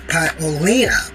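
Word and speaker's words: Paolini: Let's see, it's Jasmine Paolina, that's how you pronounce her name, a 5'4", 5 feet, 4 inch tall player, won that match, ranked at number 43 in the world.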